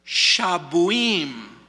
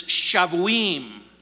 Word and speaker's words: shavuim: The word weeks translates the Hebrew word shabuim, or shavuim. You can pronounce it with a kind of a hard B or a hard V, but it's shavuim.